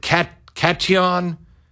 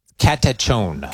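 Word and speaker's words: katechon: He uses a word that most Christians have never even heard of, and it's, how do you say it? Is it cation? – Catechone.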